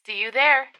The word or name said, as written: see you there